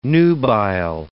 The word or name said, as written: nubile